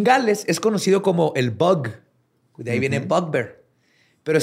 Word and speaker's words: bwg: Se escribe B-W-G. – Pero obviamente porque es Gales. – Pero se pronuncia bug.